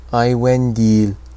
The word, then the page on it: Aiwendil: Quenya